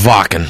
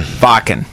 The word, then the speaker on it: Wacken: It's pronounced Wacken. – Wacken, right. Because it's German.